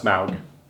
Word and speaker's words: Smaug: It's pronounced Smaug.